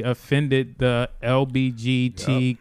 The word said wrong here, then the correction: LGBTQ: if did I say that right? – LGBTQ. LGBTQ. Yeah.